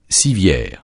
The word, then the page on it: civière: francais